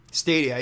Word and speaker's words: Stadia: It's called, I don't know if I'm pronouncing Stadia. It right, but I think it's Stadia. – Stadia, yeah, Stadia. Essentially, and I'm very cautiously optimistic.